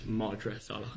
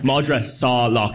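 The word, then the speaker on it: Madra Salach: Sorry, it's Madra Salak. – Madra. – I was asked to... – Have I been saying it wrong the entire time? – I don't know. I didn't. That's the first time I've caught it. I was asked to intervene with pronunciation. – You are. I told you. – We haven't made it easy on you as Irish. – Madra Salak. Madra Salak. If you think of like saw and lock.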